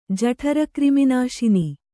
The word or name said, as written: ಜಠರಕ್ರಿಮಿನಾಶಿನಿ